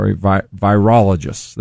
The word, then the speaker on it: virologists: Virologists.